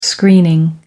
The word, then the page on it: screening: American English